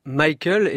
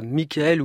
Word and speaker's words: Michael: Il y tenait à son prénom Michael et non pas Michael ou Michel.